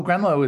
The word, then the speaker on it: Gruenloh: Oh, Gruenloh is just great.